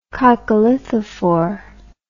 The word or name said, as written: coccolithophore